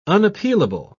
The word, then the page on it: unappealable: British English